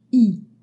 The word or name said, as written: i trema